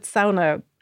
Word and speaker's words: sauna: But just to sort of go back to some of those facts about them, and actually that the word, so we said sauna earlier, which is the correct Finnish pronunciation, and apparently this is the only Finnish word in the English dictionary.